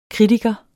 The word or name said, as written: kritiker